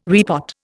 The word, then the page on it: repot: Indian